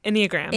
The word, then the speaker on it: Enneagram: Enneagram. – Enneagram. Like any belly button? – Yeah.